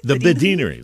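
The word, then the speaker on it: Badinerie: How do you all pronounce this? – The Badinery. – That's fine.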